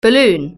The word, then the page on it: balloon: British English